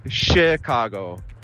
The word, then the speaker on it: Chicago: Obwohl normalerweise CH auf Englisch CH ausgesprochen wird, eigentlich ist es Chicago, als ob es SCH wäre.